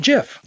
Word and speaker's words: GIF: But the thing is the actual creators of the GIF call it Jif.